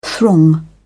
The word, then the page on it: throng: British English